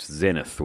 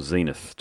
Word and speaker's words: Zenith: Zenith or Zenith, depending on where you're from in the world, is how you pronounce it there.